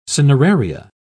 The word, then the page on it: cineraria: British English